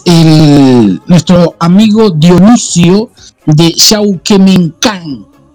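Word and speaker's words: Yauhquemehcan: Ángel Meneses, que nos está escuchando desde Yauquemencán. Eh, Sí, Está bien pronunciado.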